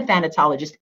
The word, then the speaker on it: thanatologist: Like, I'm a thanatologist, and you did pronounce it right.